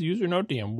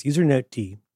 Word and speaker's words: usernoted: For the past couple of weeks, a process identified as usernote D. Did I say that right? You pronounce the D as D because it's a daemon.